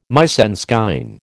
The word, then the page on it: mise en scène: American English